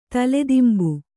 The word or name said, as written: ತಲೆದಿಂಬು